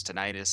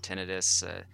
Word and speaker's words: tinnitus: They can also help with a condition that's known as tinnitus. Some folks pronounce it tinnitus. And yeah, jury's still out. I prefer tinnitus myself.